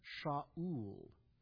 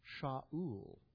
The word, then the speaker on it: Shaul: The name Saul in Hebrew is pronounced Shaul. Shaul.